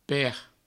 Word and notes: per: Provençal

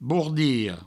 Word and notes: bordilha: bourdiho

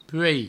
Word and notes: puei: provençal

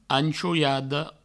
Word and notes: anchoiada: anchouiado

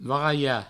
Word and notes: varalh: varai

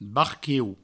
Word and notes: barquiu: barquieu; Provençal

